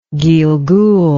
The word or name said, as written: gilgul